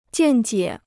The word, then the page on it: 见解: jiàn jiě